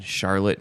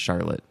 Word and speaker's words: Charlotte: It's not pronounced Charlotte. – Is it Charlotte? – Ooh. Charlotte. And I was told that it's very offensive to people in Michigan to call it Charlotte.